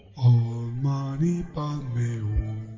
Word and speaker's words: Om Mani Padme Hum: El mantra más comúnmente usado es el de las 6 sílabas verdaderas, el cual es Om Mani Padme Hum. – Om Mani Padme Hum.